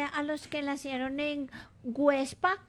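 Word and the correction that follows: Güepsa: Perdón, Huepsa. ¿Ah? Con diéresis. – Huepsa.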